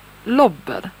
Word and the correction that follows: lober: Lober. Jag vet inte om jag uttalar det korrekt. – Ja, ska det vara lober där, ja.